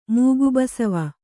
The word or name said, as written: ಮೂಗುಬಸವ